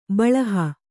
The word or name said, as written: ಬೆಳಹು